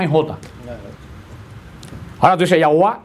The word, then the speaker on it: Yahweh: Entonces llegaron a la conclusión que se debió, pero es una suposición, haber pronunciado Yahweh. Dijeron que podía haber pronunciado, y dice que a lo mejor podía ser pronunciado el Yahweh, ese vino Yahweh, y después por una corrupción de la Y con la J, como en el siglo XI surgió la palabra Jehová, que es una corrupción de una traducción de una suposición.